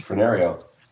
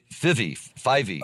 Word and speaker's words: Fyvie: Maybe you can pronounce it, F-Y-V-I-E. – Frenario. – No, this is the Bonnie Lass of Fivey.